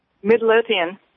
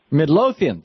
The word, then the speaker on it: Midlothian: Middletonia, Virginia. Am I saying that right? – Midlothian. – Midlothian.